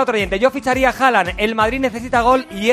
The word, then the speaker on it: Haaland: Se dice Haaland. La doble A en noruego es A, que se pronuncia O.